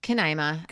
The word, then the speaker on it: Canaima: Then they do like a jump ahead to, is it Canaima? – Canaima, how you say it? That's, yeah.